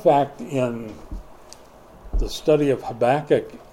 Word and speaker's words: Habakkuk: Now, a lot of people pronounce that Habakkuk. You're welcome to pronounce it wrong. But Habakkuk was the guy's name.